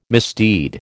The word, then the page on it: misdeed: American English